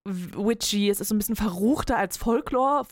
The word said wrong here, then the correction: Folklore: Das war sehr deutsch ausgesprochen. Entschuldigung, als Folklore.